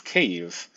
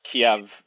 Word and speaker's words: Kyiv: And the most important city of the city-states in the 10th century was the city of Kyiv, pronounced Kiev in Russian.